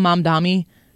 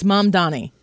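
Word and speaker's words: Mamdani: They call him Mom Dommy a lot. They can't get his name right. It's like Mom. Your mother, whose name is Donnie. It's Mom Donnie.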